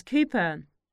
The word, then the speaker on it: Cooper: The surname is Cooper. It's spelled as C-O-O-P-E-R.